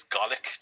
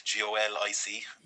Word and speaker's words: Golic: Yeah, they've Paul McGann, who went on to become um, he plays Golic, G O L I C. – Yes, no, it's not like it's, it's almost like you're mispronouncing garlic, but Golic.